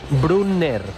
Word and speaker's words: Brunner: Paris Brunner, con 2 N's, Brunner. – Brunner Alemania. – Ahí no tiene problema el Tala en pronunciar, Bruno. – Brunner.